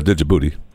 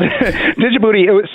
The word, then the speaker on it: Djibouti: But I noticed you didn't mention my favorite country in Africa, Djibouti. I realize that some people think that you drop the D and just call it Djibouti, but it's my favorite country, and I can pronounce it any way I want.